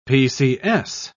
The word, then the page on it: pcs: British English